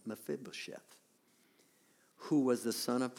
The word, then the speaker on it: Mephibosheth: No, it's not it. No, it's Mephibosheth. Is that what you said? It's a hard name to say, Mephibosheth.